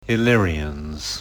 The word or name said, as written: Illyrians